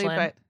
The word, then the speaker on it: Aisling: It is Aisling B, but I don't know how to pronounce her first name correctly. – Ashlyn, but Aisling B.